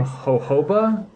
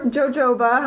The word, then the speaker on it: Jojoba: On Team Jojoba? – Yeah, or Jojoba, however you like to be, however you like to call it. I guess it's pronounced both ways.